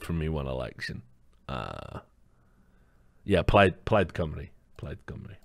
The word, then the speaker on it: Plaid Cymru: By the way, if any of you fuckers think I'm pronouncing that wrong, go look at the Wikipedia page. It is literally Plaid Cymru. That is exactly how you pronounce C-Y-M-R-U.